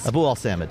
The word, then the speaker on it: Abuelsamid: Abuelsamid.